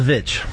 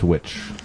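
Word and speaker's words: The VVitch: The witch or the vitch? – The vitch. – The VV itch. Yeah, that was a good movie. – Pronounced witch.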